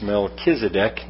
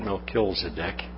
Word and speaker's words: Melchizedek: This man, Melchizedek, I always want to put an extra L in there for some reason when I pronounce it. It's Melchizedek, not Melchizedek, but it's one of those words, you know, difficult name.